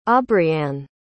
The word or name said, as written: aubrianne